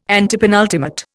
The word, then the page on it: antepenultimate: Indian